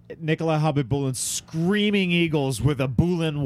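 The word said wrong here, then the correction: Khabibulin: K-H-A-B... It's, yeah. Kabib... B-I-B-U-L-A-N. – Kabibulin. – That's how you spell it. It's Kabibulin.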